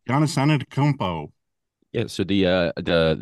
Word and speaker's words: Antetokounmpo: It is Antetokounmpo.